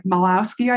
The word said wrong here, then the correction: Michalowicz: I think, Michalowicz, speak at an event.